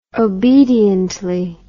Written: obediently

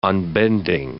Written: unbending

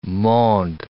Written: monde

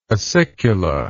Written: acicula

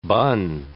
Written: bun

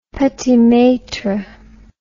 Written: petit maitre

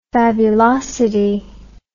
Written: fabulosity

